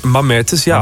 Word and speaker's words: Mamertus: Gisteren was het Mamertus. Zo spreek je het uit, of niet? Ja, hè? – Mamertus, ja.